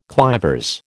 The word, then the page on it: clivers: American English